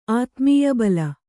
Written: ಆತ್ಮೀಯಬಲ

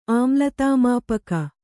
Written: ಆಮ್ಲತಾಮಾಪಕ

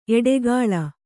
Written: ಎಡೆಗೋಲ್

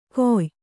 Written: ಗೊಯ್

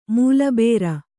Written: ಮೊಲೆಬರ್

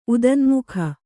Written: ಉದನ್ಮುಖ

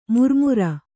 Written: ಮಿರಿಮಿರಿ

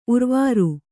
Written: ಊರ್ವರೆ

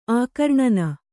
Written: ಆಕರ್ಣನ

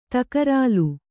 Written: ತಕರಾಲು

